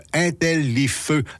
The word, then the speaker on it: Intellifeu: et ça s'écrit comme ça se prononce, Intellifeu, donc Intel, L-I-F-E-U, pour le site web au complet, là, Intellifeu Canada.